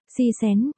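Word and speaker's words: si xén (喜神): Hỷ thần, thần vui, tiếng Trung đọc là, si xén, người chết.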